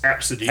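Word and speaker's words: Abcde: So the child's name is pronounced Apsody.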